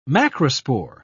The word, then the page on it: macrospore: British English